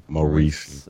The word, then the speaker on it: Maurice: Maurice.